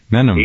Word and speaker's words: Menem: Menem, however you pronounce, M-E-N-E-M. – Menem. – He was the, Menem.